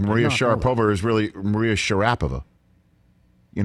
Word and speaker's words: Sharapova: Open that Russian athletes, you put the accent on the middle syllable. So remember we always say Maria Sharapova? We should call her Sharapova.